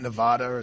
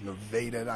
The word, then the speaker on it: Nevada: I, don't, you're supposed to pronounce that. It's not Nevada. It's Nevada.